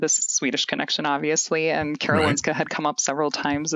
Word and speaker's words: Karolinska: And then what led you to, is it, am I pronouncing it correctly, the Karolinska Institute in Stockholm? – Yeah.